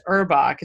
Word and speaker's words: Urbach: And joining us, our special guest for today, who we're so excited to have with us, Regine, and I believe it's Urbach.